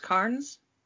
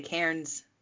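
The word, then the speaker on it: cairn: Real quick, so that's how you pronounce, it's pronounced cairns? – Yes. – Okay, yeah, because I always read it and I want to say cairns. – I think both are correct, actually. Cairns and cairns.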